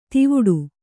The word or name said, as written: ಧವಡ